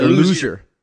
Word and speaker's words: Luzier: It's weird because like if you see the name Frazier, it's Jure. So right. – I spell my name Luzier. It sounds like Jure as well.